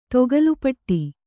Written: ತಕ್ಕೋಲಪುಟ್ಟಿ

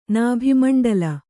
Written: ನಭೋಮಂಡಲ

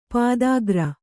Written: ಪದಗಾರ

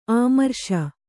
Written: ಅಮರೇಶ

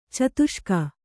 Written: ಚತುಷ್ಕ

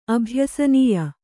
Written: ಅಭ್ಯಸನೀಯ

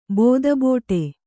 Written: ಬೋದಬೋಟೆ

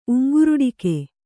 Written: ಉಂಗ್ರುಡಿಕೆ

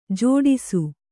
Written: ಝಡಿಸು

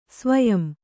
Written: ಸ್ವಯಂ

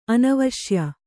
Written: ಅನವಶ್ಯ

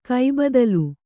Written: ಕೈಬದಲು

